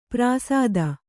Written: ಪ್ರಾಸಾದ

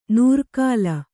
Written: ನೂರ್ಕಾಲ

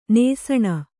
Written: ನೆಸಣ

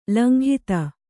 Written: ಲಂಘಿತ

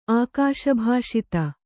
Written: ಆಕಾಶಭಾಷಿತ

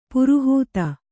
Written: ಪೌರೋಹಿತ

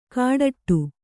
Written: ಕಾಡಟ್ಟು

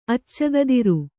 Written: ಅಚ್ಚಗದಿರು

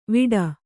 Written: ವಿಟ್

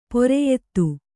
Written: ಪಿರಿಯಾದಿ

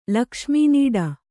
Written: ಲಕ್ಷ್ಮೀನೀಡ

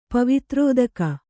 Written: ಪವಿತ್ರೋದಕ

